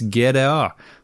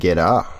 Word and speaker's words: GTA: Og så påpeker hun da at GTA uttales GDA på sørlands. Det har da aldri Magnus Tellefsen sagt når han var med. GDA.